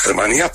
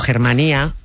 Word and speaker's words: Germanía: Utilizan las Germanías para sustentar ese poder. – Lo que pronunciamos los castellanos a lo bruto como Germanía es en realidad Germanía, en Valencia. Germa es hermano, Germanía, hermandad.